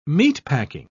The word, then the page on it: meatpacking: British English